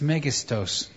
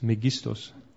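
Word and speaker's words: megistos (μέγιστος): How do you say that, megistos? – Wie sagt man das? – Megistos. – Megistos. – Right, is that it? Megistos.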